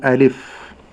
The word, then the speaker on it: ألف: نعم، أولا كلمة ألف، بعض التالين لكتاب الله يقول ألف. – نعم. – طبعا هذا خطأ. – النطق الصحيح فضيلة الشيخ؟ – ألف. – ألف. – نعم. – ليس ألف. – ليس ألف. – نعم. – لا يقول ألف. – نعم. – ثم بعد ذلك أنتبه إلى الهمزة المفتوحة، أقول أ، لا أقول أ. – نعم. – لي، لا أقول لي، ألي، لكن ألي. – ألف. – ألف لام ميم. – نعم. – ثم بعد ذلك سكون الفاء، لا أقول ألف لا.